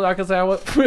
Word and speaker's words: Nakazawa: Michael Nakazawa is going to do the whatever his fucking name is. – It's you Michael said it right. Nakazawa.